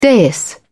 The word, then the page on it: dais: British English